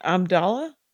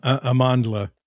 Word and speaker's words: Amandla: Bitches Brew and Amandla? – Amandla. – Amandla. Accent on the wrong syllable.